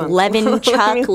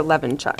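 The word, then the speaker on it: Levonchuck: It's like Levin Chuck, Levin. I did not know that. – Yes, Amber Levinchuk. – Amber Levinchuk.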